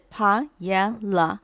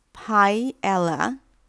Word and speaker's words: paella: 先来说 paella 这个字的发音，paella 是三个音节的单字，分为 p a e l l a。出版社提供的音档发音是 paella。另外呢，字典的发音还有 paella、 paella、 paella。